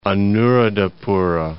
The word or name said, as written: Anuradhapura